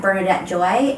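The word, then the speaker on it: Bernadebt Joy: Bernadette Joy. And it's spelled B-E-R-N-A-D-E-B-T, so with the word debt in it.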